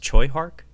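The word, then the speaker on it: Tsui Hark: Uh, Sammo Hung has a cameo, and uh, I, I, think it's pronounced Choi Hark. It, it's spelled Tsui. Hark, Uh, he plays one of the the three.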